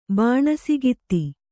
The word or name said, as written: ಬಾಣಸಗಿತ್ತಿ